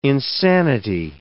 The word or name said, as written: insanity